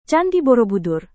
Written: candi borobudur

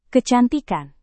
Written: kecantikan

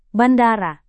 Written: bandara

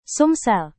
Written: sumsel

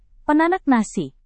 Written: penanak nasi

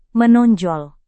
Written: menonjol

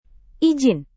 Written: ijin

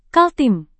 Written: kaltim

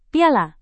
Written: piala

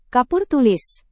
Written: kapur tulis

